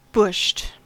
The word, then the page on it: bushed: American English